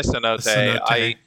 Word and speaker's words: cenote: It's apparently cenote. No, I was not aware that English just took the more Spanish pronunciation for the word, but neat.